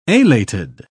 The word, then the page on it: alated: British English